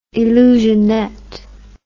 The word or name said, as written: illusionette